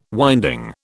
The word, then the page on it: winding: British English